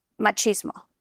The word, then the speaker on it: machismo: Do you know, there's an interesting thing that I've learned recently, that you know how there is harmful machismo? Mm-hmm. You know what machismo, right? Like am I saying it right? Yeah. There's also harmful language.